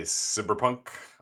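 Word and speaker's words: CBR•PNK: I'm thinking about CBR plus PNK, which is that the proper way to pronounce the name of the game? – Yeah, absolutely. I just tend to say cyberpunk or cyberpunk. Okay.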